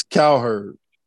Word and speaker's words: Cowherd: How do you say this nigga's last name? – It's Cowherd. – Cowherd.